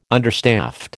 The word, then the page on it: understaffed: American English